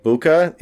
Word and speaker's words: Bucca: La, la, la, well, it's actually it's so in the card game Seven Seas based off of it's spelled La Boca like mouth, and it's like Buca in the um in the the Seven Seas second edition B U C C A.